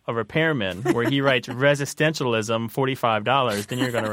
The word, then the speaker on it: resistentialism: Resistentialism, resistentialism.